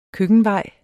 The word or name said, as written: køkkenvej